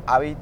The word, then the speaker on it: Havit: Habit se escribe H-A-V-I-T, Habit.